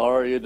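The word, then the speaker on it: Ariya: what's your thoughts and experiences with Ariel Daivari? I probably butchered his first name, my bad. – Oh, Arya Daivari, everybody.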